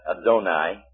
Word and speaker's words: Adonai: Don't forget, you say El Shaddai, not Shaddai. And Adonai, and so on. When you have an A-I together, it's pronounced as I.